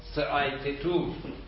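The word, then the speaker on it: Theaetetus: افلاطون در سایتتوس، سایتتوس ببین اینطوری سایتتوس تتوس، این در محاوره سایتتوس برای معرفت دو شرط آورده در سایتتوس.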